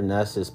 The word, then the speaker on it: Knust: I love Jennifer Nuss's point of view. K-N-U-S-T is how you say her. It's her valid last name, Jennifer Nuss.